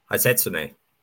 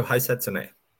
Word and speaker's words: Hisatsune: Hisatsune. – That's the one, yeah, that one. – Rio Hisatsune.